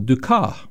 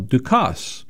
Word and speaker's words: Dukas: Some would say it is pronounced Paul Dukas. Others would say it is pronounced Paul Dukas. Well, the French decided that language protocol requires that the S be silent, the final S be silent, and the name, with broadcasters will forward from the 1955 period on, be Paul Dukas.